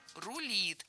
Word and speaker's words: рулит: Обратили внимание, спикер сначала сказал «рулит», ну а потом как бы исправился и несколько раз произнес «рулит». А какой же вариант правильный? В словарях зафиксировано «рулит». И во всех других формах ударение падает на окончание.